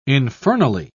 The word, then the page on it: infernally: British English